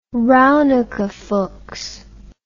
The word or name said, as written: reineke fuchs